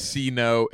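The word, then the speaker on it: Sea-No: For the Christmas album, his name is C-No. It's spelled S-E-A hyphen no.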